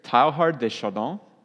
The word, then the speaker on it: Teilhard de Chardin: His name is Pierre Teilhard de Chardin, I think. My wife says that's a close enough pronunciation.